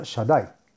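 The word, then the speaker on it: Shaddai (שדי): las letras Shin Dalet Yud, que se pronuncia Shaddai.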